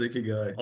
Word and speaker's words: Ikigai: And it's basically, I pronounce it Ikigai. And my publisher went down this whole path of how do you pronounce that. And they actually made me choose. They said, there are Japanese people on either side of how to pronounce this. And so I chose Ikigai, although it sounds dumb.